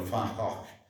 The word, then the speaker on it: Van Gogh: ma per loro Van Gogh, la pronuncia è più o meno Van Gogh, perché la V si pronuncia F come in tedesco e la G e la H si aspirano, per cui è venuto un suono culturale e quindi è un po' così.